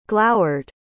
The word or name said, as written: glowered